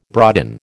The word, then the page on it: broaden: American English